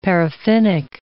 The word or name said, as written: paraffinic